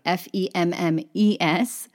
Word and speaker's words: femmes: And notice I did not pronounce the S on the end of femme, right? Because that's, that's the end of my little phrase. There's no word with a vowel that follows it. So, les femmes, the women.